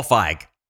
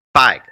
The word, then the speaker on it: Feig: Feig? – Paul Feig. – Feig.